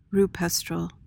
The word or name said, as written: rupestral